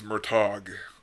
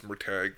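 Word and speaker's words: Murtagh: I thought it was Murtag, yep. But it's Murtag. – Murtag. – Yeah, I get what you're saying. – It's Murtag.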